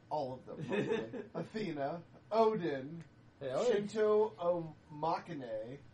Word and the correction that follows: Omoikane: Spell it for me. – O M O K A N E. – Omoikane. – Omoikane, there you go.